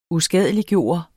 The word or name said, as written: USKADELIGGJORTE